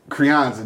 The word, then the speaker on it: Crianza: Crianza, that's how you say it.